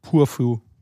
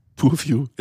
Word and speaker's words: Purview: Wie würdest du es aussprechen? – Purview. – Purview, ja genau.